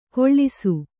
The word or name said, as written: ಕೊಳ್ಳಿಸು